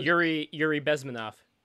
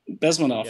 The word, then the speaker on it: Yuri Bezmenov: Yuri Bezmenov. – Bezmenov.